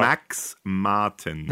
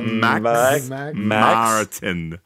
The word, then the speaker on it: Max Martin: Max Martin. – Max. Hang on. Say it again. – Max Martin. – Martin. Okay, got it.